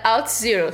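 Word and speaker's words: Auth0: Eu não falei no começo, mas eu trabalho numa empresa chamada Out Zero. Se escreve A-U-T-H Zero.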